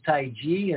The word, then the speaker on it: Tai Chi: And the correct pronunciation is Tai Chi, as if it were a J, but the energy that we're harnessing is called Qi.